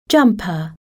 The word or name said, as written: jumper